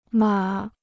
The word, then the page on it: 码: mǎ